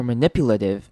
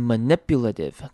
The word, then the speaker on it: manipulative: Manipulative，好，看到后面的那个 a manipula 也是一样念成轻音 manipulative，好，后面的 t i v e 的 t 也是一样会念成轻，会念成像是 d 的声音 manipulative，有没有发现重音在 n 这个声音上面 n，然后接一个短母音 i manipulative，好，所以这两个字要特别注意 deceptive。